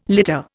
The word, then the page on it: litter: Indian